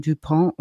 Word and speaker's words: Dupont: In diesem Fall geht es um den ähm, Milliardär John Dupont, auf Französisch ausgesprochen, Amerikaner, gebürtig französischer Abstammung. Seine Vorfahren waren Hugenotten und sind vor der Französischen Revolution aus Frankreich nach Amerika geflohen. Also so eine alte Familie sind die Duponts. Ähm, der John Dupont im amerikanischen DuPont ausgesprochen, ich sag mal Dupont, ich krieg's irgendwie anders nicht hin, wird geboren im November 38 in Philadelphia, ganz im Osten der USA.